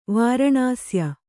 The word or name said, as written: ವಾರಣಾಸ್ಯ